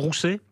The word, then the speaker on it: Ruse: Oui, Ruse, on dit plus facilement Rousset, oui. Mais ça s'écrit Ruse ou Rousset.